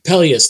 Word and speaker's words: Peleus: I think that's how you pronounce his name. – Peleus? – Peleus, thank you. Yes, that's correct.